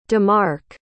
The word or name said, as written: demark